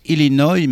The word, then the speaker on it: Illinois: Les American Breed de Chicago, Illinois. On prononce Illinois, mais ça, vous ne pouviez pas le savoir, naturellement.